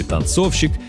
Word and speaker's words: танцовщик: И не важно, что герой этих строк – мужчина, танцовщик. Это ровно ничего не меняет. Ударение в слове «танцовщик» точно так же падает на второй слог, как и в слове «танцовщица».